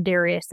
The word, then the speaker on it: Darius: when King Darius would say, or Darius, if you want to pronounce it that way, when he issued an edict and he just said, you know, here's my law.